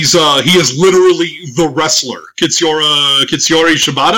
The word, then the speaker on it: Shibata: It's like, there's not too many that have similar to the Young Lions attire other than Shibata.